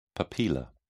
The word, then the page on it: papilla: British English